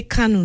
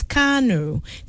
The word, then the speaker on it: Kano: Like when I went around schools for the Independence Day celebration, some people say Kano. No, it is Kano. Kano, not Kano.